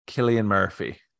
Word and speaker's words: Cillian: Okay, I'm going to say it's pronounced Cillian Murphy. – That is correct.